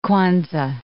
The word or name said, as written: Kwanza